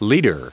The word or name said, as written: leader